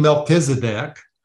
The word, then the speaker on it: Melchizedek: Like some people say Melchizedek, the priest that was mentioned in the Old Testament, what might have been, the spirit of Jesus. What do you think? – Well, I call him Melchizedek.